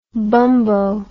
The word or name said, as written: bumbo